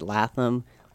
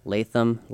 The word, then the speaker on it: Latham: that as someone, my last name is L-A-T-H-A-M, right? Uh-huh. So I get Latham, Latham, So, you know, hopefully there's no beef.